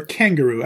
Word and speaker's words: kangaroo: Or kangaroo. I like to emphasize the first syllable because that's how Bruce Willis's girlfriend says it in Pulp Fiction.